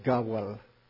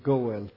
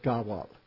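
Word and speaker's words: Gawal (גואל): Realmente en hebreo pronunciamos Gawal, ¿ok? Yo sé que en español dicen Goel, pero en hebreo decimos Gawal, el redentor pariente cercano.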